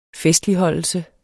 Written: festligholdelse

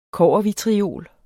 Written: kobbervitriol

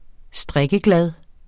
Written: strikkeglad

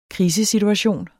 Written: krisesituation

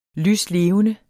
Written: lyslevende

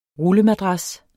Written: RULLEMADRAS

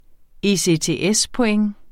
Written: ECTS-point

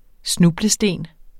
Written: snublesten

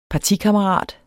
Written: partikammerat

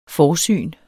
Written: forsyn

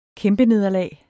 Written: kæmpenederlag

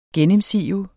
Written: gennemsive

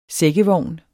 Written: sækkevogn

